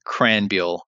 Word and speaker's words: Krahenbuhl: K-R-A-H-E-N-B-U-H-L. And I'm going to have you say that for me one more time. – Sure. It's a Christian Cranbuehl. And to be fair, I've had that name pronounced once correctly my entire life.